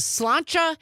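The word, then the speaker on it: sláinte: Amy from Madison texting in on the text line saying the correct pronunciation of the Irish cheers to your good health is slancha. That's how I've always pronounced it.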